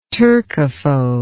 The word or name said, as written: turcophobe